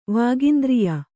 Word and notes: ವಾಗಿಂದ್ರಿಯ: Kannada

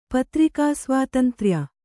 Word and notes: ಪತ್ರಿಕಾಸ್ವಾತಂತ್ರ್ಯ: patri svātantrya

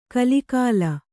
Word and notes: ಕಲಿಕಾಲ: kalikāla

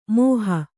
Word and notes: ಮೋಹ: kannada

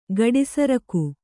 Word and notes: ಗಡಿಸರಕು: kannada

